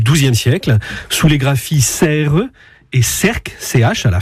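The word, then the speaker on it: Sercq: J'ajoute que la forme anglaise du nom de l'île s'écrit avec un A. Mais ce n'est pas pour cela qu'il faut dire Serques. Ce serait ignorer la prononciation locale et la prononciation française.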